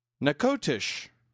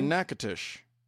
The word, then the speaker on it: Natchitoches: And she said that she lived in the one in Louisiana, and it's pronounced, and this baffles me, Nacogdoches. – Really? – Or Natchitoches. That's not at all how it's spelled. That's like not even close to how it's spelled.